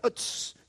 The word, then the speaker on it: Uz: By the way, that's how you pronounce that, Uz.